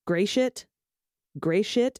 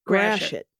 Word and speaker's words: Gratiot: but we do not pronounce it as gray shit. Instead, it's grashit.